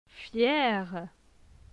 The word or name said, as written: Fier